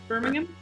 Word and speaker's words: Birmingham: Did I say it right? Birmingham? – Yep, you did.